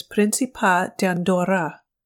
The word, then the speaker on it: Principat d'Andorra: The official name is Principality of Andorra, or the Catalan pronunciation is Principat d'Andorra. The capital is Andorra la Vella.